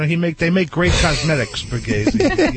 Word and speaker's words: Borghese: Am I pronouncing it right, Joey? – Borghese, yes. – Borghese. – That's right.